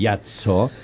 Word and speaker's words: Viazzo: lo voy a pronunciar bien en italiano, sí. Viazzo, es decir, como el primer MasterChef amateur del Uruguay.